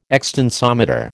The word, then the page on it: extensometer: American English